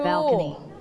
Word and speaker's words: balcony: Balcony. – Balcony. – Okay, one more time. – Balcony. – Balcony. – Balcony. Don't forget to pronounce le sound. Bal. You have to roll your tongue.